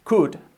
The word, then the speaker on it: could: Primero, tenemos que omitir totalmente la L, tanto en otros verbos similares como es could, podrías, escrito could, o deberías, should, escrito should, la L no se pronuncia. Could, should, por lo tanto está tampoco, would.